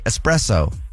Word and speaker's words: espresso: Espresso? – Okay. – There's no X. – Right, but that's a lot of people say espresso.